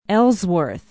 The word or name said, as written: ellsworth